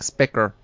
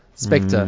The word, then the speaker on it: Spectre: I'm saying it right. It's not like Specker. Spectre. Mm. It is right. Producer Kat, it is Spectre, right? – Spectre. Sally Spectre. – S-P-E-C-R-T-E.